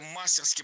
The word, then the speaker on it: мастерски: Мастерски. Как часто я ругала за такое произношение. Но теперь все в прошлом. Мастерски – это теперь тоже норма. Хотя раньше следовало ставить ударение исключительно на последний слог – мастерски. Теперь это страшное наречие можно произносить по-разному. Мастерски и мастерски – двойная норма.